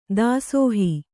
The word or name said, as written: ದಾಸೋಹ